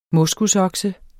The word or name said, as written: moskusokse